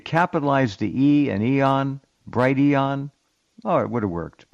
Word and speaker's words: Brighteon: Well, I'm not going to be on Brighteon anymore. Forget him. – Yeah, he still doesn't know how to pronounce his enterprise there. It's Brighteon. Not bright eon.